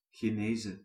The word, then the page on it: происхождение: /prəɪsxɐʐˈdʲenʲɪjə/